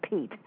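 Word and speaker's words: pith: For instance, the most sacred of the Shakti peats, it's P-I-T-H-S, but it's still pronounced like Pete.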